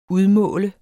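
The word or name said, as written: UDMÅLT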